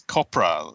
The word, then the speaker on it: Copra: Yeah, it's copra. It's spelled like cobra, except with a P instead of a B.